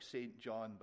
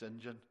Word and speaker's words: St. John: But you read his name, it looks like St. John, but it's pronounced St. John.